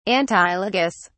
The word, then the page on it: antilogous: British English